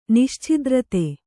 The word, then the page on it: ನಿಶ್ಛಿದ್ರತೆ: nischidrate